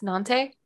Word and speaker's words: Nantes: Yes, Nantes, but you don't again don't say the S at the end.